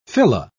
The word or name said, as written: fila